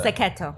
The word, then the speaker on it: Sacchetto: Did you say it right, by the way, Sacchetto? – Sacchetto. – Sacchetto. Oh, I apologize.